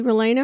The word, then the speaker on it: relleno: Relleno. It's like a double L sort of thing. Relleno. In Spanish, and all you Spanish speakers, feel free to correct my pronunciation. Relleno.